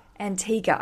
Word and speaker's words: Antigua: Antigua.